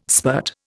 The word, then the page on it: spirt: Indian